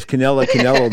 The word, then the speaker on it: Canelo: Canelo.